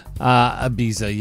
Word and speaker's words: Ibiza: Ibiza, yeah.